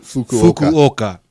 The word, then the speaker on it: Fukuoka: Fukuoka. It has a space in between there. You should pronounce both vowels separately.